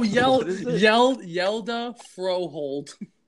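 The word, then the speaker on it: Hjalte Froholdt: Oh, listen how it's pronounced. Yida Froholt. Oh, Yelda Froholt.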